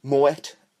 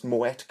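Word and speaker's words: Moët: Moet is a champagne, right? But a lot of people think it is pronounced Moe, but it isn't. It is pronounced Moet correctly.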